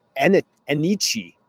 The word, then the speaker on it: Enyce: His name is Enyce, Joey. Enyce Sledge. That is how you pronounce it, according to the Auburn pronunciation guide.